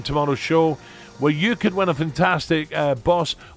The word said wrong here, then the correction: Bose: A Bose.